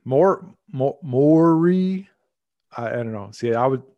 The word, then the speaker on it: Maury: So Maury. More, not Mar.